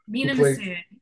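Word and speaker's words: Mena Massoud: At the hands of, I hope I pronounce this correctly, Mina Massoud. – Mina Massoud, yes.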